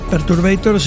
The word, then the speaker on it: Perturbator: Una persona, Perturbator, lo he dicho bien. – Perturbator, sí, es un músico francés con nombre inglés, se llama James Kent.